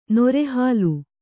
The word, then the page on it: ನರಹುಲಿ: nara huli; Kannada